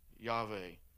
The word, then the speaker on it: Yahweh: The way that you would pronounce the name of God with no vowels in it would be, the N was Yah, and then the Veh, Yahweh.